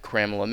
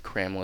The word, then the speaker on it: Cramela: I'm at Kramla Mix Show, and Kramla is spelled C-R-A-M-E-L-A. And lucky for you and me, I'm the only Kramla there is in the world right now.